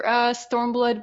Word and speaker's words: Stormblood: One last thing you mentioned is they didn't give a specific release date for Stormblood, but we...